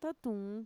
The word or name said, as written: tatuun